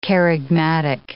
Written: kerygmatic

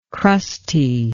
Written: crustiness

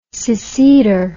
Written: seceder

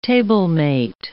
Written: tablemate

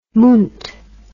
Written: munt

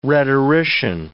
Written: rhetorician

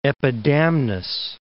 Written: Epidamnus

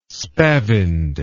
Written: spavined

